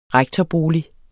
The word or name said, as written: rektorbolig